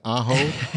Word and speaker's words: Aho: What rhymes with Sebastian Aho? Oh, yo!